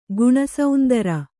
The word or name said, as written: ಗುಣಸಾಂದ್ರ